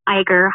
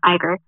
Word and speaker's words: Iger: I'm sorry, I said that wrong. Iger. – That's okay. – They were doing a question. – I didn't know, so I didn't clear it. – Right. I heard it pronounced Iger, so I'll say it that way.